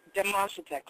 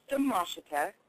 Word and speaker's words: Demoshita: Demoshita. Close enough. – Did I say it right? Dub with a D? – It's okay. It's pronounced Demoshita or Miss D for short.